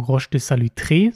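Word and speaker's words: Roche de Solutré: Unter anderem am eindrucksvollsten, und das könnt ihr auch in diesem Video sehen, ist das einmal Roche de Salutre, Entschuldigung, Roche de Salutré, so wird es ausgesprochen, und Roche de Vergisson.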